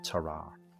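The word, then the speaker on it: Tarrare: Terrar. – So it's T-A-R-R-A-R-E, Terrar. And I've watched quite a few videos on it, and people pronounce it different ways, but that seems to be the most common, Terrar.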